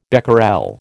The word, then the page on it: becquerel: American English